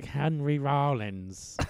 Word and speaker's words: Henry Rollins: Like Henry Rollins. – Is that how you pronounce it? – Yes.